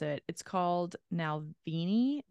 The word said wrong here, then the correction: Navalny: Now, Navalny. Nalvani.